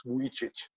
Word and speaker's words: Vujicic: His name is Nicholas James Vujicic. Okay, it's Vujicic. Sorry, I was pronouncing it wrong.